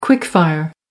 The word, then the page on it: quick fire: American English